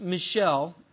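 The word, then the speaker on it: Michal: Now in verse 20, now there's another daughter, and I'm pretty sure it's pronounced Michelle. Doesn't look like that, but in verse 20, now Michelle, Saul's daughter, loved David.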